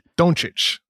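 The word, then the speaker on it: Dončić: Doncic.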